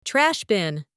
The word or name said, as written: trash bin